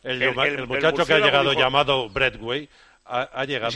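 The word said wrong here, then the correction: Braithwaite: Digo Breitway, vale.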